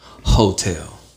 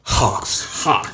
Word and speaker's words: Heauxtale: Or as my Alexa says it, Hawks. Hotel. Like, Jasmine. No, Alexa, play Jasmine Sullivan's new album, Hotel. – Playing Jasmine Sullivan's new album, Hotel. Whatever. Because it's spelled HEAUXTALE, Hotel.